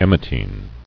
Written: Emetine

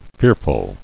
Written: Fearfulness

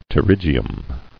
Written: Pterygia

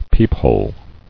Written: Peephole

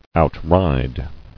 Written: Outride

